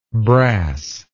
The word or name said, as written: brassish